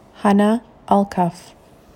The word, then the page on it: Hanna Alkaf: Malay